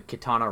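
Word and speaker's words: Qitana: There's one dungeon called the Kitana Ravel, spelled with, like, a Q. It's like Q-I-T. Q-I-T-A-N-A, and then Ravel.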